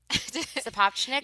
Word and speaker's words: Sapochnik: Sapochnik? – Yes.